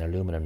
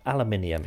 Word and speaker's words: aluminium: I'm saying aluminum now. Aluminium is the proper way to say it.